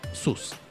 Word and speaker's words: SUS: Eh, y después tenemos SUS. Así se pronuncia. Se escribe S-U-S, SUS.